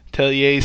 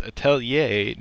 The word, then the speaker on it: Atelier: Atelier series. – No one can stop you. – And I can't get over the mispronunciation of the title words. It's Atelier, not Atelier.